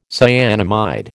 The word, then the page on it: cyanamide: American English